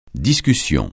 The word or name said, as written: discussion